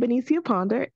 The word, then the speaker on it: Benecia Ponder: Well, I am Benecia Ponder, and I'm a story coach, book writing, and publishing mentor for inspirational authors.